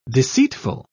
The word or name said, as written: deceitful